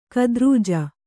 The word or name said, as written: ಗೋತ್ರಜ